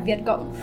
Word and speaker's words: Viet Cong: Oh, Viet Cong. – Yeah, Viet Cong. – Okay, so the G doesn't, I don't pronounce the G's, it's just a C-O-N.